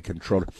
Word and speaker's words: comptroller: Lander, a former city controller, it's spelled comptroller, but you just say controller,